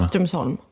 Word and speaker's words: Strömsholm: Uttalar man det Strömsholm? – Ja, Strömsholm. Du ska ha lite gnäll liksom. – Strömsholm? – Lite stön. Ja, perfekt.